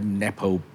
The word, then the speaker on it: Nepo: I must admit, when I read the question, so you pronounce it Nepo, right? Nepo, yeah. Nepo I, baby.